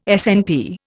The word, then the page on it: SNP: Indian